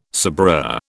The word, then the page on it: sabreur: British English